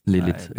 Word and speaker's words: Lylit: Wie schreibt man das? – L-Y-L-I-T. Lilith, Lilith, ja.